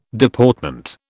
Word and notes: Deportment: British English

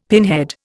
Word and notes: pinhead: Indian

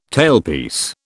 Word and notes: tailpiece: British English

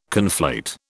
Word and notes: conflate: British English

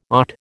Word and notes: AUT: American English

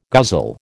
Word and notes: guzzle: American English

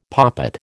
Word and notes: poppet: American English

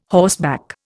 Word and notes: horseback: Indian

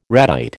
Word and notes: ratite: American English

